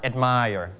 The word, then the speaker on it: admire: นะครับ Admire. นะครับ Listen two times again. The first time. – Admire. – The second time. – Admire. – Your turn. Admire. – Admire. – Admire. You stress on the first or second syllable. Second, นะครับ second.